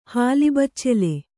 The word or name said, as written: ಹಾಲಿಬಚ್ಚೆಲೆ